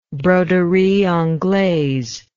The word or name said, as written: broderie anglaise